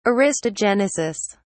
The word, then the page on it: aristogenesis: British English